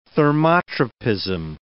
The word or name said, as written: thermotropism